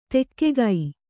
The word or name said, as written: ತೆಗ್ಗು